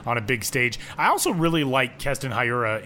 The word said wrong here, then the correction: Hiura: in the, uh, or Hura, as he said when he called me. I got to start pronouncing his name correctly.